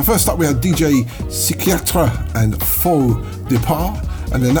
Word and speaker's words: Psychiatre: Let's play something from DJ Psychiatre. That's how you pronounce it in French.